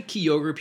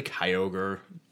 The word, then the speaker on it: Kyogre: I mean, people say Kyogre. People say Kyogre. Neither's wrong.